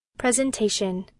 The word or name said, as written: presentation